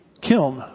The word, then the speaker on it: kiln: That's, how do you say it? Kiln. There we go. Kiln. I guess I put two syllables in there.